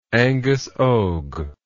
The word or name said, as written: Angus Og